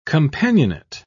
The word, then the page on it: companionate: British English